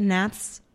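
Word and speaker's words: Hnath's: And the opening moments of Lucas, I think, is it Nath's? Is that how you say it? Nath's? Yeah. H-N-A-T-H, right? Nath's?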